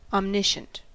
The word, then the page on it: omniscient: American English